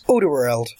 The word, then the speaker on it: Alderweireld: I think we can never pronounce. – Alderweireld. – Yeah. – Yeah, we had him at Saints for a year. We learned how to pronounce his name.